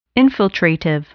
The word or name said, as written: infiltrative